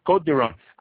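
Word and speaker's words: Côtes du Rhône: I have to point out on the Côte du Rhône, this is spelled C-O-T-E-S-D-U-R-H-O-N-E, and then the lodge or village, and then the Côte du Rhône itself. Côte du Rhône, I'm sure everyone has been to the wine stores and looked on the shelf and found the Côte du Rhône wines.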